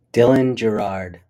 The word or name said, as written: Dylan Girard